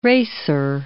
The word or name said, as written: racer